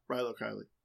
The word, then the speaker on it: Rilo Kiley: Is it Rilo Kiley? – Yeah, Rilo Kiley.